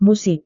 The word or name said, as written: musik